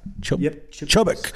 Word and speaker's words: Chubbuck: Number 37, Mr. Todd Hunter from, how do you say that? Chubbuck? What is that? – Chubbuck. – Correct. Chubbuck, Idaho.